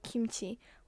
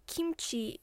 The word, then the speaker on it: 김치: Oh, by the way, it sounds like kimchi if you pronounce the word in English, but I'll be pronouncing the word in Korean, so see if you can notice the difference.